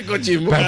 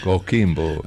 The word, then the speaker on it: Coquimbo: Coquimbo.